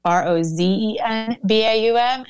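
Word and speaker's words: Rozenbaum: So it's Rosenbaum.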